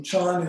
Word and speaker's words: Chan: Chan is the original Chinese pronunciation of the ideogram that in Japan is pronounced Zen.